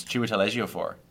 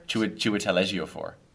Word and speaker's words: Chiwetel Ejiofor: It's Chiwetel Ejiofor.